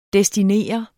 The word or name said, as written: DESTINER